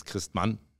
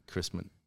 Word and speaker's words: Christmann: If you say it in German, it's Christmann.